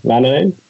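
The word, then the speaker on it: Väänänen: Como bien sabes, pues Henry Vananen, que es la, como pronunciamos en la UE, a pesar de las diéresis, pues Vananen es un jugador de referencia,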